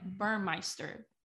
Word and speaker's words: Burmeister: It looks like Burmeister. Braxton Burmeister.